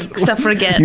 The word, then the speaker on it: Suffragette: Suffragette. – You do the hard G.